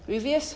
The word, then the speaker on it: grievous: And when we yearn for the honor of this world, that can become a grievous mistake. Did I pronounce that word right? Grievous? – Mm-hmm. – Grievous. Grievous? – Yes. – Oh, thank you.